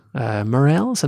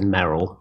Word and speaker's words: Merrell: Morels? I never know how to pronounce them. – Yeah, I've always said Merrell. – Merrell? Yeah, me too. But I know some people that say Morel.